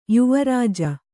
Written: ಯುವರಾಜ

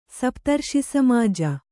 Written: ಸಪ್ತರ್ಷಿಸಮಾಜ

